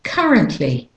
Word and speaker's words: currently: And the next word is currently. So the actual stressed syllable, the main stressed syllable, is the first syllable here. Currently.